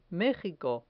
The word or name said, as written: mejico